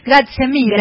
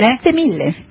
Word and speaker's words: Grazie mille: Grazie mille. – Grazie mille. – Grazie mille.